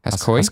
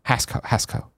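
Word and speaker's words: Hascoët: Hascoet. I know these French words a lot. I know you don't say the last syllable, the last letter, like the last consonant.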